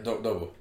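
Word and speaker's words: DUBU: You say his name? – Yeah, Dobo. – Dobo.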